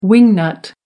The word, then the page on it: wing-nut: American English